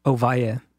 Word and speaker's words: Ovalle: Ovalle. – Ovalle. Yeah. Not La Jolla, like my Arkansas friends say, La Jolla.